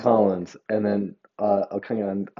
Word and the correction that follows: Okongwu: Onyeka Okongwu.